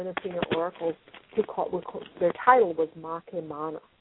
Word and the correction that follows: Makewana: Sorry, Makewana. – How do you spell that? – Makewana, Makewana.